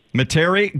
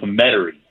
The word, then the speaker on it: Metairie: In just a little bit, but very pleased to have joining us here this morning, head golf professional at, I'm pronouncing this right, Metairie Golf Club outside New Orleans. Is that correct there? – It's not, but it's close enough. Metairie.